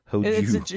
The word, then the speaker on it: Hojo: Hoju? – Hoju. – Is she Japanese? – Yes. – Yeah, you said it right.